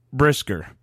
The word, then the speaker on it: Brisker: You go out and you draft two rookies on the defensive side of the ball in Gordon and Brisker.